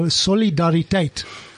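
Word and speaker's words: Solidariteit: So, Solidaritet. Is the correct pronunciation.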